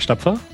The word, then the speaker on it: Stapfer: So, hey, my name is Patrick Stapfer.